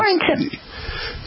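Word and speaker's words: CO2RE Intima: I realize yeah, Core Intima. – Because I'm not sure how to pronounce the C-O-2-R-E, but I guess I could just Core Intima. – Yeah, we call it the Core Intima.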